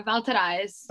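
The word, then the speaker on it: Val Thérèse: Is it Valterice? Is that right? – Yeah, Valterice. – Okay, so it is French.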